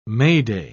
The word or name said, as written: mayday